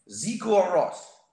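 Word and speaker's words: Sigur Rós: You went to a Sugar Rose concert, huh? – Zigur Ross is is that how you say it? – Yeah, yes, a Scandinavian guy.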